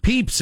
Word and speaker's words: Pepys: Pepys. It's Pepys, not Pepys, as I've been saying.